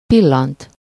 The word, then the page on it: glance: American English; GenAm; IPA: /ɡlæns/